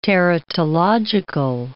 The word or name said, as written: teratological